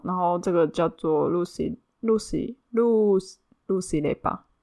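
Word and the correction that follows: ruis: Louis 雷巴，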